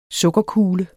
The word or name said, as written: sukkerkugle